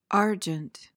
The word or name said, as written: argent